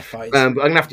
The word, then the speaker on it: Fiennes: Yeah, Fiennes.